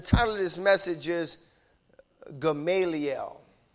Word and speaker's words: Gamaliel: Gamaliel is the proper pronunciation of his name.